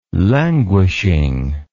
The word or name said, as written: languishing